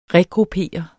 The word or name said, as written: regruppere